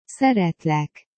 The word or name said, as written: szeretlek